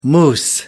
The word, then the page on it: mousse: American English